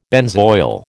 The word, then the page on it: benzoyl: American English